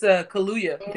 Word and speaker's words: Kaluuya: Daniel Kaluuya or something like that. – Who? – It's Kaluuya. Daniel Kaluuya.